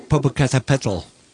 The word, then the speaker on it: Popocatépetl: Popocatépetl.